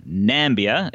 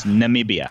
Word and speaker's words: Namibia: Jeff is definitely closest, but this is a country that Donald Trump called Nambia. It's Namibia. – That's right.